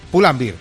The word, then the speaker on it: Pull&Bear: ¿Pull&Bear o Pull&Bear? ¿Cómo se dice? – Pull&Bear. – Pull&Bear, muy bien.